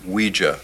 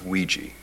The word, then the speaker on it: Ouija: It's pronounced Ouija, not Ouija. It comes from the French and German words for yes, we and ya, Ouija.